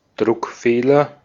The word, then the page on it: coquille: /kɔ.kij/; France